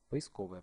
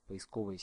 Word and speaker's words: поисковая: В первую очередь хочу обратить внимание на правильное ударение в слове «поисковый». В соответствии со словарем следует произносить поисковая оптимизация, поисковый запрос, поисковое слово, поисковая система. Можно делать ударение на первый слог – поисковая оптимизация, но этот вариант является устаревшим.